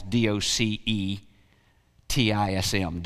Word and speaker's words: docetism: If you want to spell that, it's D O C E T I S M, docetism.